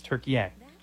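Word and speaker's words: Türkiye: But they now say the pronunciation is Turkey-ang.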